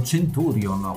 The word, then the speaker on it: Centurion: Abbiamo deciso di dedicarlo a qualcosa di molto personale per noi, facendo Centurion, se vogliamo usare la pronuncia latina originale dell'antica Roma.